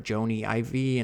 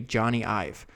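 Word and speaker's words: Jony Ive: So sometimes I had to look up how to pronounce his name because I've heard it Joni Ivy and all this other stuff. But according to Google, it's supposed to be Johnny Ive.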